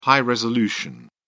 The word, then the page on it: high-resolution: British English